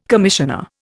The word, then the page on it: commissioner: Indian